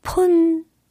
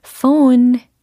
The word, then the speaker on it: phone: 폰, 폰, 그죠? 그치만 그 발음이 아닙니다. phone, phone, phone 이렇게 해주셔야 되겠습니다. 일단 로 시작을 해야 되고 그 다음에 모음이요. on, on 그리고 끝소리 음 이렇게 끝난 거 들으셨죠?